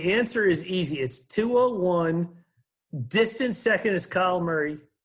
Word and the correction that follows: Kyler Murray: Am I saying his name right? – Kyler Murray.